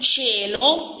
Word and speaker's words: cielo: Agora aqui tem algumas palavras que podem ser escritas com C-I-E, por exemplo, cielo, céu, cielo, se escreve C-I-E-L-O, mas se pronuncia mesmo che. Não tem diferença de pronúncia entre C-E, C-E e C-I-E.